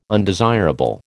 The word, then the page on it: undesirable: American English